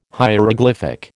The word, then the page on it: hieroglyphic: American English